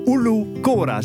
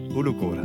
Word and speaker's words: Ulu Kora: Het monument heet trouwens Ulu Kora. Zeg ik dat goed? – Helemaal. – Ulu Kora, top.